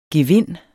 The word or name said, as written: gevind